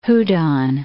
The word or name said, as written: Houdon